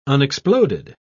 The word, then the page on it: unexploded: British English